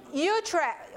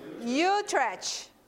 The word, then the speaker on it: Utrecht: This was voted in Utrecht. How do you say that? Utrecht.